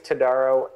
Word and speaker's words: Todaro: So it's James Todaro, M-D.